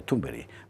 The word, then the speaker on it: Thunberg: Quiero decir que personalidades psicopáticas como la de Greta Thunberg, no sé qué, Carlos Esteban nos enseñó que se pronuncia Thunberg.